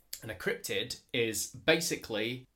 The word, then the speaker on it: cryptid: how do I say it? Say it again. – Cryptid. – Cryptid. – Cryptid. So it's C-R-Y-P-T-I-D.